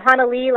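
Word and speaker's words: Hanalei: Hannah Ella, is that, am I pronouncing that right? – It's actually Hannah Lee, like from Puff the Magic Dragon.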